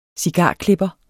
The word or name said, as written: cigarklipper